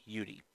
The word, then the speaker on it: YuuRi: Let me pronounce that for you. Is Y-U-U, capital R with an I, YuuRi.